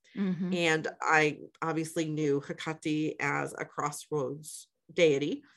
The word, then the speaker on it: Hecate: I'll float between Hecate, because I know that's how most people know her nowadays, and Hecate, yes. – which is my understanding is closer to the Greek pronunciation of her name. – Yep. – It just somehow naturally more natural to me, to call her Hecate.